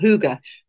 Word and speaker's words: hygge: It's called hygge.